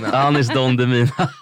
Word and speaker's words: Anis Don Demina: Anis Don Demina. Det är så dåligt. Donis... Nej, Anis Don Demina.